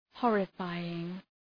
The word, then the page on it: horrifying: ‘hɔ:rə,faııŋ